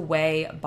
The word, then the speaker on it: Ouai: And next is the Ouai body scrub. So the brand that's, like, O-A-U-I, that's how you pronounce it. You pronounce it Ouai, to my knowledge.